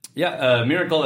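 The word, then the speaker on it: Mirakl: Miracle is, I think of as sort of the original enterprise class solution. And it's spelled peculiarly for listeners that haven't heard it before. It's M-I-R-A-K-L, which I'm sure they thought was cute, but it's kind of a They're name.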